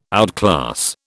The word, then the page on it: outclass: British English